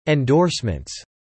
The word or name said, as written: endorsements